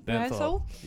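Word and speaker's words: Bernthal: How do you say his last name? – Berenthal.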